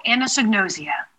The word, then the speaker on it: anosognosia: It's called anosognosia. – Okay. Explain to our audience what this is and talk about your experience with it. – So anosognosia, according to the Cleveland Clinic, is a condition where one cannot recognize other health conditions or problems that one has.